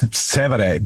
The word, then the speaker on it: Trastevere: Yeah, Trastevere. I can't say it right. Yeah. – And then there's certain. Trastevere. – I'll take your word for it. But that's Trastevere.